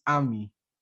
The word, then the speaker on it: Amy: You spell it Amy, but it's pronounced Ami.